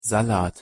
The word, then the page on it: salat: German